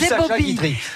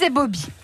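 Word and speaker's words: ébaubie: Ah moi, j'avoue que je suis ébobie. Je suis ébobie. En trois syllabes.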